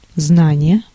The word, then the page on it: знание: [ˈznanʲɪjɪ]